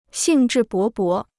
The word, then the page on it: 兴致勃勃: xìng zhì bó bó